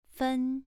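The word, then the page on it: fen: tone 2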